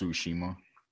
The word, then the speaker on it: Tsushima: It's pronounced Tsushima.